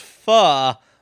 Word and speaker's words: pho: Which has a sushi bar and specializes in Vietnamese po, or pho, however you pronounce it. – It's pronounced pho, but I pronounce it pho to piss off my girlfriend.